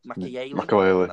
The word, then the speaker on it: Claude Makélélé: I mean, I was speaking to you, about it only a couple of weeks ago about, is it Claude Makélélé? – Makélélé, that's how you pronounce it.